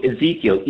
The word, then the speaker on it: Ezekiel: Ezekiel, Yeah, no, you're not pronouncing it wrong, but it's also a bread in the freezer of the fancy supermarkets.